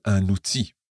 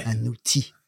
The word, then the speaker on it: outil: First of all, un outil. That's spelled O-U-T-I-L. – But you don't say the L. – Ouais. – Un outil.